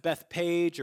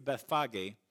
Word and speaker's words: Bethphage: Now, people pronounce it Bethpage or Bethphage. It's a P-H-A-G-E at the end, but in Greek, it's Bethphage.